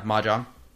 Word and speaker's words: Mahjong: And then later, after the Mahjong, is that how you pronounce it? – Yeah, Mahjong.